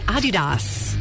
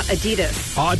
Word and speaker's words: Adidas: Did you know that most of us pronounce Adidas wrong? It's actually supposed to be Adidas. – Really? – Yeah, slight emphasis on the first syllable, not Adidas. – Adidas. All right. – Yeah, that German. It's a German company, so.